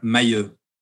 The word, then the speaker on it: Mahu: Could you pronounce your last name for us? – Sure. Mahu. – Mayu. – It's French. It's not easy.